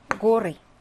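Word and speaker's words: 고리 (горы): 자, 산은요, 러시아어로 가라라고 하거든요. 근데 얘가 지금 복수형태잖아요. 고리. 복수 대격 형태가 되겠습니다.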